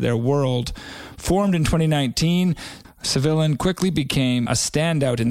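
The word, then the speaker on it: Cevilain: Civillain, that's C-E-V-I-L-A-I-N. I had to reach out to the boys on Instagram to figure out how to pronounce that name, but it's Civillain.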